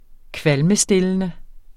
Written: kvalmestillende

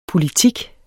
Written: politik

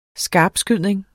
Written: skarpskydning

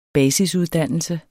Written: basisuddannelse

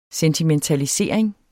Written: sentimentalisering

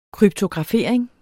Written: kryptografering

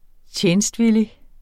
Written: TJENSTVILLIGT

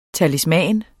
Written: talisman

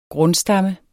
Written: grundstamme

